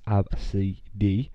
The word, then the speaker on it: Abcde: A gate agent laughed at the name, pronounced Absidy, which is A-B-C-D-E.